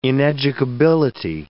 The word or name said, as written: ineducability